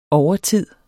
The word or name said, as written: overtid